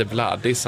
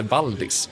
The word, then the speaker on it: Valdis: Och hon, Svantesson, råkar ju säga, hon byter plats på några bokstäver här, han heter inte Vladis, han heter Valdis. Så att den här personliga touchen, ja, den blir ju lite ihålig om man säger fel namn.